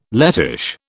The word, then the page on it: Lettish: British English